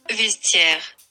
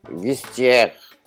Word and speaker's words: vestiaire: قولي بقى بتنطق ازاي فيستير فيزتير فيستي وات معلش قولي تاني كده معلش فيستير فيستير فيستير ايوه